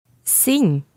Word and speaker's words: sim: Sim. – Repeat this again. – Sim. – Now, that word is actually spelled S-I-M, but there's a nasal sound at the end of it.